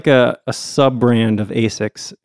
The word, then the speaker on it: ASICS: So in Japanese, it's pronounced ASICS.